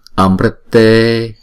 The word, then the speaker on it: Amrite: Ajare, Vijare, Vimale, Amrite, Amrite. Chữ R có dấu nặng này chúng ta đọc là Ri. Ghép chữ T này là Rit, thấy không?